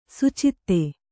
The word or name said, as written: ಸುಚಿತ್ತ